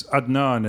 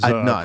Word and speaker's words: Adnan: It's Adnan is Adnan, proper okay, the correct way.